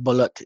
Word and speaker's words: balut: Does she eat blue? – Oh, balut, you mean? – Because that's a thing, right? – But yeah, it's pronounced balut.